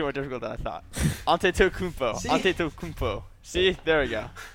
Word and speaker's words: Antetokounmpo: Well, we have Giannis Antetokounmpo. – Yeah, that's actually you actually still screwed it up. Antetokounmpo. That's how you say it.